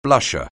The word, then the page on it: blusher: anglais